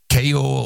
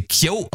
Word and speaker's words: Keo: Die Band wird K-E-O geschrieben. Ich dachte irgendwie K.O. oder wie auch immer, aber anscheinend werden sie K.O. ausgesprochen, leitet sich vom irischen Nachnamen der Brüder ab.